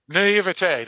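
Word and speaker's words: naïveté: I'm praying to God I said that right, because it's a word I always see in books. What is it? – Naivete? – Naivete, that's right.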